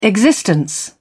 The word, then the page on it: existence: British English